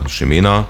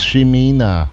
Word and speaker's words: Ximena: Und da haben wir Ximena, spricht man so, obwohl es mit X geschrieben wird, und das ist die spanische Fassung. Also Schreibweise.